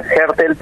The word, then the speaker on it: Aertel: Sí, mi apellido se lee Aertel, pero comúnmente me nombran como se escribe.